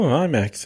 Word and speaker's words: IMAX: basically about how they shot it with these IMAX. Why does that sound weird? Am I saying that wrong? – No, IMAX. Yeah, that's right.